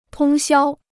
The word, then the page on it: 通宵: tōng xiāo